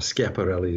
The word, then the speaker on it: Schiaparelli: That was Schiaparelli was the or Schiaparelli, his name should be pronounced.